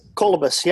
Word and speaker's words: Kalibas: Kalibas, yeah. – Oh, Kalibas. I had the wrong emphasis on the wrong syllable.